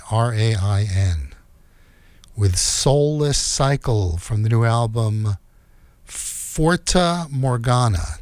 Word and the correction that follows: Fata Morgana: Did I say that right? Let's see. No, Fata, F-A-T-A, Fata Morgana.